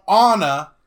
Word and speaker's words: Anna: And now Anna, I said it right.